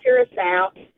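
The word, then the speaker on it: Curaçao: It kind of spelled like Caracas or something, but it's pronounced Curacao.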